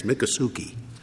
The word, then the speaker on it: Miccosukee: Yes, Miccosukee.